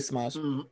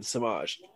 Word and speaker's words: Semajh: But go ahead and we're going to say Samaj. My name, Samaj. So I'm sure you all can imagine. But let me tell you the origin story for those who are curious. So my name, Samaj. It is spelled S-E-M-A-J-H.